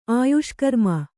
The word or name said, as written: ಆಯುಷ್ಕರ್ಮ